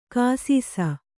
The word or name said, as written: ಘೈಸಾಸ